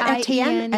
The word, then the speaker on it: Etienne: When I got my my Anye shoes, you know, that little shoes with the A, or there were purses, the E-T-I-E-N-N-E. – Oh, yeah. Yeah, yeah, yeah. Etienne? Is it Etienne? – Etienne.